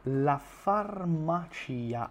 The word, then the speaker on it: farmacia: La farmacia, attenzione alla pronuncia di questa parola, non è farmacia, farmacia, la farmacia, la farmacia.